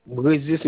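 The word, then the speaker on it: Brézier: C'est Brésil Marius. – Ça s'écrit comment, Brugier? – Brésil, c'est B-R-E comme Brésil. B-R-E-Z-I-E-R comme Brésil. Brésil. Z-I-E-R. – Alors, Bruzier? – Non. Non, B-R-E.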